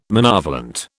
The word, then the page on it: monovalent: British English